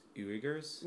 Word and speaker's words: Uyghur: Essentially, Chinese officials have said that tightened security measures and limits on the religious practices of You're saying it's pronounced Uyghurs? – Uyghur. – Uyghurs? – Mm-hmm.